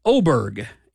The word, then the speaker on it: Oberg: It is pronounced Oberg, is what Daniel says. A hard G at the end.